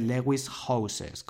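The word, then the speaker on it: Lewis Howes: Se escribe Lewis Houses, como casas en inglés, ¿vale? Pero se pronuncia Lewis House.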